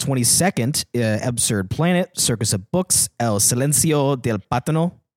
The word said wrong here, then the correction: Pantano: Did I say that right? – I don't know. What's Pantano? Pantano, excuse me.